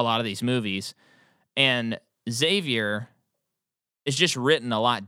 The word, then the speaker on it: Xavier: Xavier.